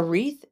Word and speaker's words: wreath: A wreath is a fun word. It is spelled W-R-E-A-T-H. The W is silent and the T-H is unvoiced.